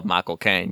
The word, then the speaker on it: Michael Caine: Yeah, I just remember the trailer with Michael Caine because I love Michael Caine because if you say his name right, it sounds like you're saying my cocaine.